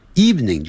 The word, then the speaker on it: evening: Tomorrow 也是这样子，明天 T-O-M-O-R-R-O-W 你明天想要做什么 What would you like to do tomorrow 你今天晚上想要做什么 What would you like to do this afternoon this evening evening 就是晚上 E-V-E-N-I-N-G 下午是 afternoon evening 这个呢我们很容易读错哦 E-V-E-N-I-N-G 我们可能会读成三个音节